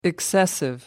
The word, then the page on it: excessive: American English